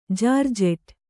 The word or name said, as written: ಜಾರ್ಜೆಟ್